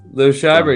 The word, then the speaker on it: Liev Schreiber: Liev Schreiber, yep.